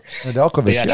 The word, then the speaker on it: Nedeljkovic: Nedeljkovic, yeah. – Tristan Jarry, like that's, I don't hate that cap hit, but what I'm wondering is with Nedeljkovic and Jarry, what are they doing with Casey DeSmith? – I don't know.